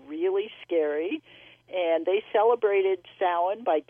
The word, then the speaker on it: Samhain: it kind of goes back to an ancient Celtic festival, and it looks like it's spelled Samhain. It's actually pronounced Samhain, despite all the movies that have characters called Samhain in them.